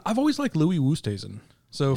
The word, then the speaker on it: Louis Oosthuizen: Louis Oosthuizen.